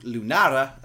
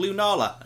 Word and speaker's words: Lunala: Everyone was calling it Lunara as the Japanese pronunciation. And I was like, it'll probably be called Lunala.